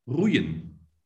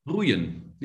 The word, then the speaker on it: roeien: How do you say rowing in Dutch? – Roeien. – Roeien? – Roeien, yeah.